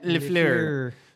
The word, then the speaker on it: LaFleur: Matt LaFleur. You have to say it like that, by the way. Matt LaFleur.